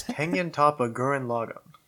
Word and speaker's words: Tengen Toppa Gurren Lagann: How do I say it? – I believe the full name of the anime is Tengen Toppa Gurren Lagann.